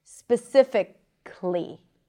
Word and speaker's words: specifically: So when you practice words with the ali suffix, remember that it's not pronounced as an a, not specifically or accidentally, but it's reduced to a schwa.